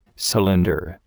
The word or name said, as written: cylinder